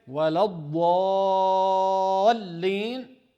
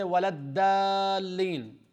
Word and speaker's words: وَلَا الضَّالِّينَ: Some people as well, when they say وَلَا الضَّالِّينَ, they don't pronounce it as ضَاد because that's the Arabic language, the language of ضَاد. Usually some people pronounce it as D, D in English. D is different from ضَاد. So instead of saying وَلَا الضَّالِّينَ, they might say وَلَا الدَّالِّينَ, دَا instead of ضَا.